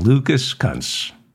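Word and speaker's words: Lucas Kunce: I'm pronouncing it properly. Lucas. I said Lucas correctly. As in Lucas Kuntz.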